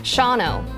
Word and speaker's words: Shawano: The hardest ones, Oconomowoc was hard for me, and Shawano, because there's a W. – And another syllable.